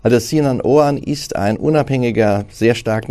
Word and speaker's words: Sinan Oğan: Jetzt könnte es auf einen Mann ankommen, den die wenigsten von uns wahrscheinlich schon mal gehört haben, Sinan Oan, ich hoffe, ich habe es richtig ausgesprochen, äh, der dritte richtig.